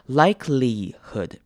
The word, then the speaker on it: likelihood: Likelihood 这个字要注意的是两个 I 的念法，那它第一个 I 呢是念长母音 I 的声音 likelihood，就跟原本的 like 一样 likelihood。那中间的 I 有没有发现它念成长母音 E 的声音 likelihood likelihood，而不是念 I 的声音，所以是 likelihood。